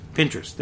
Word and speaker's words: Pinterest: How do you pronounce that? – Pinterest. – There you go.